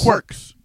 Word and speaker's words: quirks: How do you pronounce that word? Quirks? – Quirks. – That's what I thought I was saying. I said corks. They all sound the same.